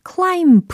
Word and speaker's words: climb: climb the mountain이 아니라는 거 알고 계시죠? 예, 끝에 있는 b, c, l, i, m, b의 그 끝 b는 묵음 처리가 된다.